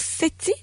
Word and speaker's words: SETI: Il nous reste encore un petit 2 minutes, Christian, et j'ai vu sur la fin qu'on a un don considérable pour SETI. C'est bien comme ça qu'on le prononce? – Oui, c'est ça.